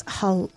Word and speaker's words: halloumi: It's halloumi.